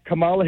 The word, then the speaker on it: Kamala: First of all, it's Kamala. You've been hearing it pronounced correctly now for months, if not years.